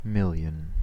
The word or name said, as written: million